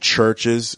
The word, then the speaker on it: CHVRCHES: I started listening to this group called Churches, and it's spelled CHVRCHES, and they're a Scottish electronic band that was formed in 2011, and they've got, how many albums do they have?